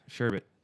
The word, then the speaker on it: sherbet: Yeah, sherbet. – Yeah, but people say that. That's the thing. It literally says right here, nope, there's no R in the second syllable.